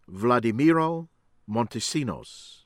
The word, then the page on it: Vladimiro Montesinos: American English